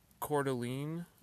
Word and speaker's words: Coeur d'Alene: And we were, I don't know, close to Coeur d'Alene, I believe is how you pronounce it, Coeur d'Alene, Idaho.